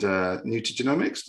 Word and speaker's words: nutrigenomics: So Kim's joined us to talk about what she's into, mainly neurofeedback and nutrigenomics. Did I say that right? – Yeah, you did.